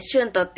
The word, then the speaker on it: xuntos: 例えば、スペイン語だと、 JUNTO で、フントって言うんですけど、それをガリシア語だと、 XUNTOS でシュントって読むんですね。